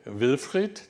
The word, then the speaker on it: Wilfried: So Wilfried, German, has an IED at the end.